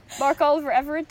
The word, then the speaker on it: Mark Oliver Everett: Mark Oliver Everett does.